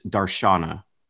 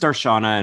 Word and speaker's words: darshan: It's usually pronounced darshan. – All right. That's a good place to start. – It might be darshana in the Sanskrit pronunciation, but darshan really means beholding and seeing with one's own eyes.